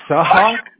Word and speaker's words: Sahak: Well, actually, my name is Sahak, like S-A-H-A-K.